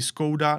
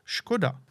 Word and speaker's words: Škoda: V Británii říkají skouda, nebo když už někdo ví, že to S s háčkem znamená Š, tak říkají Škouda ale nikdy byste je nedonutili říkat škoda.